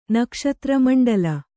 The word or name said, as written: ನಕ್ಷತ್ರಮಂಡಲ